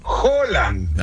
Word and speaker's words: Haaland: Ahora voy a pronunciarlo como me dijeron hoy, porque me corrigieron la pronunciación, un noruego además, que no se dice Erling Haaland, se dice Erling Haaland.